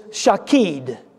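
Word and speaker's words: shakeid (שָׁקֵד): The word for almond branch, we'll say it in the Hebrew, but it is shakeid, spelled S-H-A-K-E-I-D if we were to spell it with English letters.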